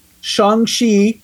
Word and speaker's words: Shang-Chi: Shang-Chi. Shang-Chi is how you say it, first of all and it has my husband in it, so we're gonna we're gonna pick that.